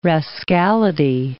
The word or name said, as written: rascality